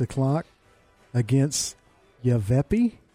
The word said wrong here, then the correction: Yavapai: I have never seen that before. – Yavapai. Spell it for me. – No, I think it's Yavapai. Yavapai.